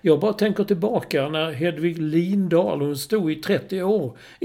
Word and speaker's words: Lindahl: Hon gick ut och sa det, från och med nu ska det uttalas Lindahl.